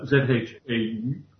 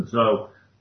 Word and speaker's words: Zhou: Then there was a paper by ZHOU from China in 2020. I understand that is pronounced more like Zhou than Zhao.